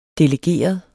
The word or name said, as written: delegeret